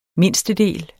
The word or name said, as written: mindstedel